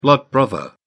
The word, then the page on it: blood brother: British English